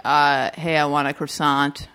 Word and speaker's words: croissant: And then, the one with the yellow hair, she says, Beth Barrett's character goes, oh, it's croissant, the T is silent.